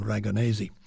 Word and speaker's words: Ragonese: And the leader and pianist on that is Michael Raganese. He's of Italian heritage, so I guess you should really pronounce his name Raganese.